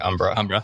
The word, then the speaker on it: Umbra: So you work at Phoenix, but you also write research at Umbra. Is that the right way to say it? – That's right, Umbra.